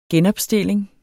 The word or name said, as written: genopstilling